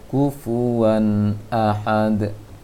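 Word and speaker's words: Kufuwan ahad (كُفُوًا أَحَدٌ): Kufuwan ahad. Itu sudah dengung. Seharusnya tidak dengung. Dijelaskan saja tanwinnya. Kufuwan ahad. Karena tidak ada hukum dengung di surat Al-Ikhlas.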